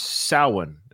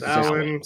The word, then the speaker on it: Samhain: So long ago, it was called, now I thought it was pronounced like you, Samhain, just like you see it, but it's Scottish or Celtic. It's actually pronounced Samhain. Is that right? – Samhain.